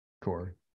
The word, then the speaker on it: Corps: Corps. You don't pronounce that.